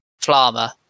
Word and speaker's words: Flamme: Flamme, that's it, like, rhymes with armor, yeah, Flamme, had developed because she was pretty much revealed to be essentially the originator of humanity's magic.